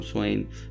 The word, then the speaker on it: Samhain: Halloween's origins date back to the ancient Celtic festival of Swain, or Samhain, as I think the Celts pronounced it that way, who lived 2,000 years ago, mostly in the area that is now Ireland, the United Kingdom, and northern France.